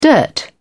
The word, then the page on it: dirt: British English